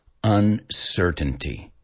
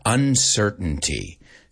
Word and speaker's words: uncertainty: Vamos a decirlo porque es un poco difícil pronunciar esta. Uncertainty. Certain. Si lo vas a pronunciar a la americana. Ahora, se puede pronunciar perfectamente uncertainty, right? Uncertainty, right? Certain. Pero muchos dicen uncertainty. Los americanos, uncertainty. Uncertainty. Nos comemos muchísimas.